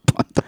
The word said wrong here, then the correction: Bumble: I feel like... – Sorry. I know enough about these sites to know it's pronounced Bumble.